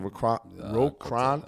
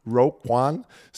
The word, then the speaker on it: Roquan: Oh, Euer All-Pro-Linebacker Roquan, Roquan Smith, das ist doch richtig ausgesprochen. – Ro, Ro, Roquan Smith ist wieder im Training seit zwei Tagen am 20.